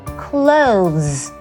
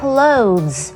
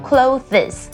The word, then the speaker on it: clothes: OK, clothes, clothes, 这是正确的发音哦，因为很多人常把它发成 clothes, 这是错误的。 OK, clothes 的拼法是 C-O-O-T-H-E-S, 其实这个单字只有一个音节，而且后面的是发 z 的声音， clothes, OK, 下次不要再念错咯。